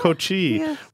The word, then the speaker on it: Kochi: Did I say that right? – Kochi, yeah. Kochi.